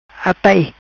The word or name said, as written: Atai